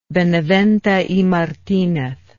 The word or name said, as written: benavente y martinez